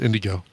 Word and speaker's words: NDGO: They perform as Indigo, but it's spelled N-D-G-O, 4 letters, N-D-G-O, but pronounced Indigo.